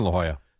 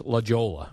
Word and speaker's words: La Jolla: We went to the beach in La Jolla. – It looks like it should be pronounced La Jolla. – Yes. – but it's actually La Jolla, Jerry. – J-O-L-L-A.